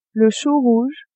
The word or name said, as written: chou rouge